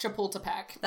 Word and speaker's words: Chapultepec: And so in September of 1847, one of the most famous battles of the Mexican-American War was fought at Chapultepec. – That was really good. – Yeah, apparently, it is pronounced Chapultepec.